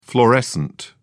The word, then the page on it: fluorescent: British English